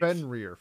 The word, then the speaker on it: Fenrir: Is that how you pronounce that wolf's name? I'm really bad at words. – Fenrir, I believe. – Fenrir.